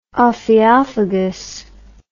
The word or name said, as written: ophiophagous